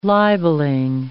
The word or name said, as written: libelling